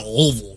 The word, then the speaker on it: Louisville: We're at Louisville. Not Louisville. – You got to say it like, yeah, peanut butter stuck in the roof of your mouth. Louisville.